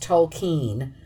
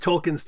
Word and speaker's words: Tolkien: But just to be even more annoying, they have recorded interviews with Tolkien's descendants, and they pronounce it slightly differently as Tolkien, with a slight elision between the E and the N, blurred together.